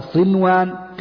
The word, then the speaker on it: صنوان: دنيا بنيان صنوان تنطقها هكذا صنوان قنوان.